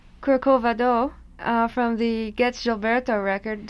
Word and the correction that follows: Corcovado: Did I say that wrong again, Astrid? – Corcovado. Okay. The just the accentuation was in the wrong syllable then, so.